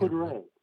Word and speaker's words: Couderay: Did you say Corduroy? – Am I saying that wrong? It's spelled phonetically. It looks like Corduroy. Corduroy? – Ends with an A. – Oh, no. Did I get Couderay. – Couderay. Oh, is that how you say it?